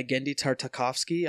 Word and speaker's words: Genndy Tartakovsky: Yeah, so this one is actually by Genndy Tartakovsky. I think that's how you pronounce his name.